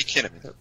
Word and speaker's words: Kinnaman: Rhymes Melanie Kinnaman. – Rhymes with cinnamon. Kinnaman.